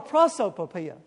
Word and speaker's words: prosopopoeia: It's called a prosopopeia. P-R-O-S-O-P-O-P-O-E-I-A. Yeah. P-R-O-S-O-P-O-P-O-E-I-A.